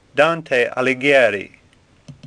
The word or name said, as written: Dante